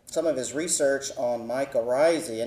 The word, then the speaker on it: mycorrhizae: And if you have trouble remembering that name, if it's new to you, I just tell my students at Texas A&M University, just think of your Irish cousin mycorrhizae and you'll never forget it again.